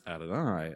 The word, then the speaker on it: Adonai: It's this, if you read a Hebrew copy, you'll see the Hebrew word yod hey vav hey, and above that are the vowels for Adonai instead of that word. And the reason is that they pronounce Adonai as you're reading it.